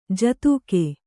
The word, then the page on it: ಜೊತ್ತಗೆ: jottage